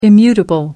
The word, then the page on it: immutable: American English